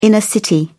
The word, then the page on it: inner city: British English